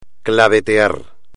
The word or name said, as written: clavetear